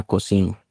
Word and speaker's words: Kozin: Ese bar se llamaba Cocin, en cantonés. Son dos palabras. Se escribe K-O-Z-I-N.